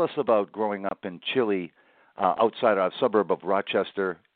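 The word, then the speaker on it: Chili: Rhymes with highlight. I grew up on a dirt road called Stallman Drive. It was off of Ballantyne Road, south of the city of Rochester, in the town of Chile.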